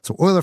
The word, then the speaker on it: Euler: So Euler Finance, by the way, not Euler, it's pronounced Euler, but it's spelled like Euler. So if you hear me say Euler, just imagine it's spelled Euler in your head.